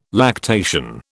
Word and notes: lactation: British English

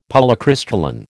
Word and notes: Polycrystalline: American English

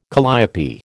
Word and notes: calliope: American English

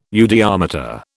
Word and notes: eudiometer: British English

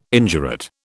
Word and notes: indurate: British English

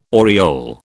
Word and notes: aureole: British English

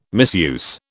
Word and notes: misuse: British English

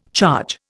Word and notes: charge: Indian

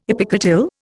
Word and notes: epicotyl: Indian

